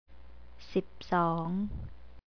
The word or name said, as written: sipsong